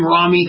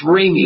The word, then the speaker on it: Rami: And if I call him Rami, that's wrong. It's Rami, like do re mi.